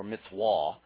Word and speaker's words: mitzvah: Mitzvah in Hebrew is the word, or mitzvah, in the way we want to pronounce it, is the word commandment.